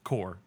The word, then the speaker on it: Corps: Core, yeah, you said you pronounce it core, but yeah, core.